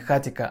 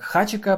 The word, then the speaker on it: Хатика: Вообще, читается как хатика, но кто-то прочитал, видимо, как хачика, потому что пишется через CH, но на самом деле это и читается хатика.